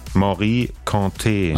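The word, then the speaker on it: Mory Kanté: Wie wird's ausgesprochen? – Morikante. – Achso, guck mal. Das war also falsch.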